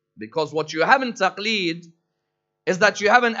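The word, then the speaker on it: taqleed (تقليد): Our emulation of them is normally known as taqleed. Taqleed, if you look at the word which Iraqis will sometimes pronounce as glada, is normally a chain which is put around somebody's neck.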